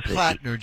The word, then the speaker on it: Platner: Graham Platner. Just think Flatner, but it's Platner.